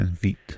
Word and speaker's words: Veidt: Yeah, Veidt.